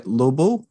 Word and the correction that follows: Lobot: is that how you pronounce it? – Lobot. – Lobot. Mm. Yes. – Short for lobotomy.